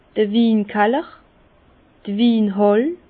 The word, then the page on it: d'r Winkaller: Bas-Rhin; Herrlisheim